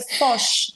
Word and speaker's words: Foch: Porque eu falei, né, e lembra do som do inglês, e daí ficou assim, todo mundo dando risada, porque a pronúncia é Foch.